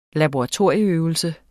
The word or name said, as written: laboratorieøvelse